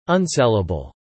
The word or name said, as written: unsellable